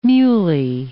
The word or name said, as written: muley